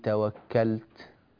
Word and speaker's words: توكلت: لاحظ أنك عندما قلت عليه توكلت هكذا يكون النطق، لاحظ فمي جيدا، عليه توكلت، ولا تقول عليه توكلت، توكلت، هذا الذي عملته اسمه إشمام. هل أنت تقصده؟